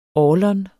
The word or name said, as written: orlon